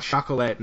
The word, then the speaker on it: chocolat: I wanted to cue up the scene in I Love You, Man, when he's saying, we want chocolate, and he goes, it's chocolate. And he's like, it's chocolate. No, it's just called chocolate. That reminded me of that scene. – It's technically pronounced chocolat, but that's fine.